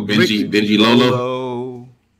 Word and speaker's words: Benji Lolo: Benalulo, Blade the Barbarian. – Oh, Benji Lolo. – Okay, Benji Lolo, I'm sorry. Excuse me. It's spelled like this.